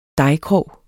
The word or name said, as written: dejkrog